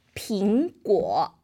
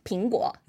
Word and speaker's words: 苹果: And why we send apple is meaning because 苹果, they have the 苹. It's the same pronunciation. It's 平安.